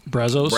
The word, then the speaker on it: Brazos: They moved to the Brazos. How do you say that? Brazos? – Brazos, yep.